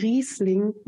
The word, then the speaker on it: Riesling: Okay, that's a Hugel Riesling Große Laue 2011. – Thank you. I could not have said it quite as authentically myself, but it's a great classic Riesling.